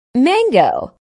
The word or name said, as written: mango